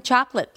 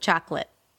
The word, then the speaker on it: chocolate: Just remember, it's two syllables. Chocolate, primary stress on the first syllable, and then the second syllable has a schwa, so it's a reduced vowel.